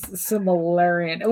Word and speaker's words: Silmarillion: It's like I'm not Tolkien, so I'm not doing like the Silmarillion here, or whatever this Silmarillion, whatever. – Yeah, you said it right.